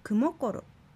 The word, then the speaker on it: クモコロ: 私が眠るという場合はクモコロと発音します。アクセントはもになります。